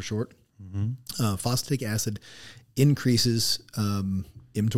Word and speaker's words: mTOR: but the mTOR. Am I saying that right? – mTOR. Yeah.